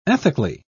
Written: ethically

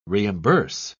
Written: reimburse

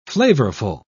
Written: flavorful

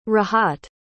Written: rahat

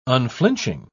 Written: unflinching

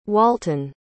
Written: walton

